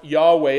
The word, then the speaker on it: Yahweh: We think it should be pronounced Yahweh.